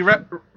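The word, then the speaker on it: Reiniger: Her name was Lottie Eichinger. Am I pronouncing that right? – What? – The director of the movie. – Lottie Rettinger. – Rettinger. Sorry, why did I say Eichinger? Lottie Rettinger.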